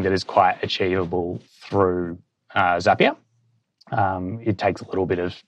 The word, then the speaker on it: Zapier: I got my mind blown when someone told me it's Zapier because it rhymes with happier.